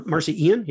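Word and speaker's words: Marci Ien: Marcy, I don't know how you pronounce her name. – Marcy Ian. Yep.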